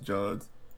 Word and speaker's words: Johns: Is it Jeff Johns or is Jeff Jones somebody else? – You're right. It's what you said. I just keep going Jones because okay. It's spelled that way, but it's pronounced Johns.